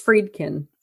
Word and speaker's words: Friedkin: Is it Fredkin? Friedkin? How do you pronounce it? – I think it's Friedkin.